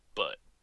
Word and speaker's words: Butte: It's pronounced Butte, but Butte.